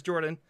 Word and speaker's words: Jorphdan: My name is Jordan, with a silent PH in the middle, and I'm joined always by my wonderful co-host, Sir Lucian, over at Sir Lucian Gaming.